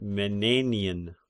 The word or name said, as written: Menenian